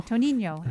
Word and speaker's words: Toninho: Toninho? – Toninho. And it's spelled T-O-N-I-N-H-O.